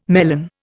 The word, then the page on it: melon: Indian